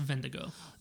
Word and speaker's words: Wendigo: It's it's spelled Wendigo, but I think it's pronounced Vendigo. – Vendigo.